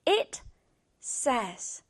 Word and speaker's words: says: It says. say까지만 있으면 say 발음을 하지만 s가 뒤에 붙이면 says라기보단 says. It says.